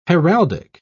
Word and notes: heraldic: British English

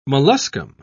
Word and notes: molluscum: British English

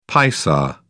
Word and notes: paisa: British English

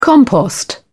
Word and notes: compost: British English

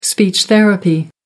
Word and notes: speech therapy: American English

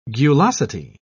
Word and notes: gulosity: British English

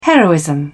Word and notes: heroism: British English

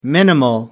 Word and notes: minimal: American English

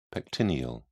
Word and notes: pectineal: British English

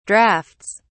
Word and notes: drafts: British English

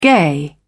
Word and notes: gay: British English

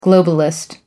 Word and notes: globalist: American English

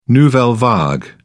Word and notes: nouvelle vague: British English

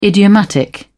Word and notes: idiomatic: British English

